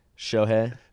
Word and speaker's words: Shohei: Shohei. – Whatever. – How hard? Why is that hard for you to say? What the hell? – Shohei. – It's two syllables, bro.